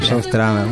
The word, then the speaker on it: Joe Strummer: Exacto, Joe Strummer.